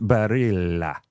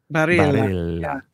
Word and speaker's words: Barilla: Lori says it's not Spanish, so the double L is not pronounced. Why, she is correct. – Yeah. – Lori's correct. So I listened to a pronouncer while you were talking. – Right. – If you imagine it like an Italian accent, it's Barilla. – Barilla.